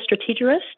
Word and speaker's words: Strategerist: And so I am so pleased to have the opportunity to be back on on Strategerist. If I even said that right. Yeah, you got it. Exactly.